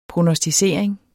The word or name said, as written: prognosticering